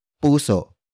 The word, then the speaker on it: puso: Puso. Puso. Puso is spelled as P-U-S-O.